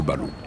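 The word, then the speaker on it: Barouh: Pierre Le Balou, en japonais on prononce comme ça, Pierre Barou, ça se dit Pierre Le Balou.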